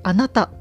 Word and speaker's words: 私: 私」と書いて「あなた」と読むそうなんですけれどもですので北山義雄さんの「あなた」という作品が展示されています。